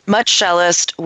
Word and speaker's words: Much Shelist: Um, so, your law firm, Much Shelist, did I say that correctly? – Yeah, you got that right. – Whew, great. – Um, Much Shelist, which serves the cannabis industry, as, as we've mentioned.